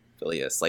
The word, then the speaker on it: Phileas: Phileas. Like Phileas Fogg, the character from Around the World in 80 Days.